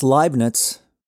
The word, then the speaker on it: Leibniz: That's Leibniz. Now that's two major embarrassing mispronunciations in a row.